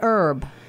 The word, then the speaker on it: herb: Like you would say an herb. – An herb. – An herb. You don't pronounce the H.